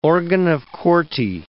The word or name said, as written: organ of Corti